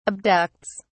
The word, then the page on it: abducts: British English